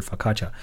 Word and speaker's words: Focaccia: 那这种面包叫做 Focaccia，它最后也是一个 i a 结尾的一个单词，但是呢，这个 i 也是没有音的，它最后读出来就是 r 的音，也就是 Focaccia。